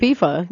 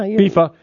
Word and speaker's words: FIFA: FIFA. Yeah. – FIFA. Okay. – I mean, really, F-I-F-A. – Look at me. – F-I-F-A.